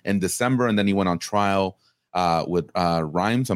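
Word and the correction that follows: Reims: I hope I'm saying that correctly. – Rem, there you go.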